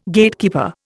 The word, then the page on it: Gatekeeper: Indian